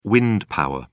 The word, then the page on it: wind power: British English